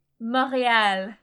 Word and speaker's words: Montréal: Montréal.